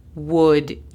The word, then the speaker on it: would: Y en inglés se diría Would you? would es un poquito complicada, pero no te dejes intimidar. Imagínate que la pronunciación es W-U-D, would you.